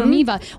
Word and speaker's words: ThermiVa: So we wanted to have you in to talk vaginal rejuvenation, and then am I saying it correctly? Thermiva. Thank you. Mm-hmm. Thermiva, which is the procedure essentially that helps women deal with it.